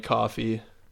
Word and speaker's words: Kofi: Kofi Kingston.